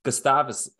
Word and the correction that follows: Gustafson: I'm going to butcher that. There's the name that I butchered. Is it Gustafson? – Oh, it is?